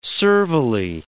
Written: servilely

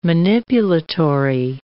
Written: manipulatory

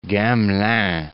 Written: Gamelin

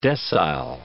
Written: decile

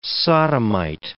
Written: sodomite

